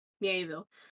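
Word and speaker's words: Miéville: When you say cosmic horror, I've read China Melville, is that how you pronounce it? – Mieville.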